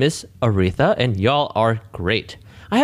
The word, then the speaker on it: Aretha: Urethra. – Urethra. – This comes from Urethra Franklin. – Aretha. Sorry, wait. How do you say that name? – Aretha.